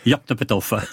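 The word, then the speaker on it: Yoknapatawpha: Ça se prononce comme ça s'écrit. Yorktopetof.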